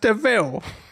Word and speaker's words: DeVille: It looks like devil, but it's pronounced DeVille.